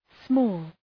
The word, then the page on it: small: smɔ:l